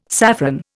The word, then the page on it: saffron: Indian